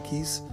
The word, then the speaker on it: Keys: día en que nació Alicia Keys case o Kiss, dice Kiss, se escribe Case.